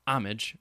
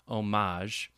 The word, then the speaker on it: homage: Elster has previously complained that good old homage was losing out to homage in havens for the better educated like National Public Radio. And for defenders of the H pronunciation, homage just adds insult to injury. So I think he means the H... homage? He wrote the H pronunciation homage, and he's writing it out phonetically.